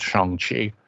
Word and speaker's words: Shang-Chi: I'm hoping to see Shang-Chi when it comes out and remember that it's pronounced Shang-Chi.